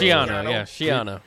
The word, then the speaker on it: Schiano: Okay, what we're gonna do is we're gonna have the coach, and we're gonna let the coach, Greg Schiano. Did I say that right? – Schiano.